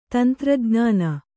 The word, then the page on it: ತಂತ್ರಜ್ಞಾನ: tantra jn`āna